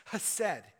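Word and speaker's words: hesed (חסד): And steadfast love is an interesting Hebrew word that is pronounced hesed.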